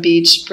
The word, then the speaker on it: beach: Beach 里的 e 是长音，所以是 beach。而 bitch 中的 e 是短音，所以是 bitch。